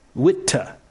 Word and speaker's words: Witte: Andre from Toronto has our only entry in pronunciation gate this week, which is that he says when spelled W-I-T-T-E, the pronunciation is more witta than wit.